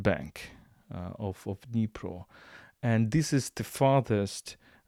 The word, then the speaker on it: Dnipro: Let us remind you that Kherson is located on the river Dnipro, the biggest river in Ukraine, or you can call it Dnieper according to Russian pronunciation, but Dnipro according to Ukrainian pronunciation.